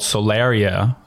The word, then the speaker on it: Solaria: And now we're on this spacer planet called Solaria. I think I'm pronouncing that right.